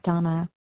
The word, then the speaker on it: Dana: This is Donna B., recovered compulsive overeater and bulimic, calling from Denver, Colorado. My name is spelled D-A-N-A, but pronounced Donna.